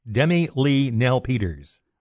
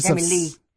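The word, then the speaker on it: Demi-Leigh Nel-Peters: Did I say that right? – No. – Well, how do you say it? – Demi Lee-Nell Peters. – Oh, Demi Lee-Nell Peters. – Demi Lee. – Demi Lee. Okay, of South Africa said yes.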